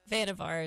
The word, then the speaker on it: Vanvar: Is it Vanvar or Vanvar? – Vanvar is right. – Vanvar, so three syllables. – Yeah, three syllables.